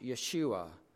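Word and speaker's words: Yeshua (ישוע): In Hebrew, the word is pronounced Yeshua, which is commonly translated Joshua in English, meaning Jehovah saves.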